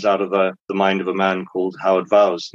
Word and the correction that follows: Voss: Now he's, or Voss, I apologize, Voss, I always mispronounce his name.